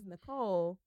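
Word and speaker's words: Nichole: Like, my middle name is Nicole, but it's N-I-C-H-O-L-E.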